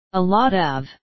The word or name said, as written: a lot of